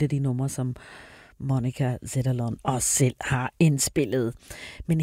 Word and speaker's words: Zetterlund: Og her kommer hun til at lave en koncert, som hedder Tribute to Monica Zetterlund. Det er den 6. juli. Og jeg tror lige, jeg kom til at udtale Zetterlund lidt forkert. Det er Zetterlund, Monica Zetterlund, Tribute to Monica Zetterlund.